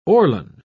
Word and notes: orlon: British English

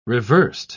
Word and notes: reversed: British English

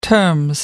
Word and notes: term: American English

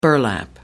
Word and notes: burlap: American English

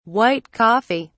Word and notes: white coffee: British English

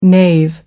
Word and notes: nave: American English

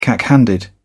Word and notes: cack handed: British English

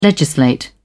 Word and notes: legislate: British English

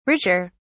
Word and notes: richer: British English

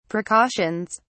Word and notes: precautions: British English

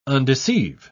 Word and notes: undeceive: British English

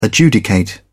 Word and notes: adjudicate: British English

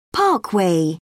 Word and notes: parkway: British English

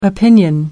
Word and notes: opinion: American English

